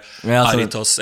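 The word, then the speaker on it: Jarritos: Visste du at jaritos på spansk haritas? Det sånn det uttales.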